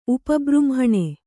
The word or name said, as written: ಉಪಬೃಂಹಣೆ